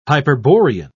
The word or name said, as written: hyperborean